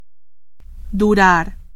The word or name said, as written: durar